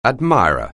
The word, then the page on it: admirer: anglais